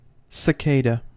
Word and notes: cicada: American English

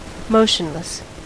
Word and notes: motionless: American English

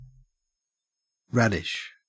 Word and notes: radish: Australian English

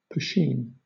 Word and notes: poteen: British English

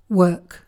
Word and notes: work: British English

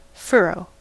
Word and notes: furrow: American English

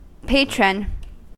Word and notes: patron: American English

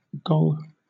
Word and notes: gong: British English

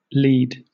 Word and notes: lied: British English

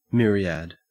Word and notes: myriad: Australian English

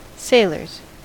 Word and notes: sailors: American English

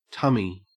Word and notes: tummy: Australian English

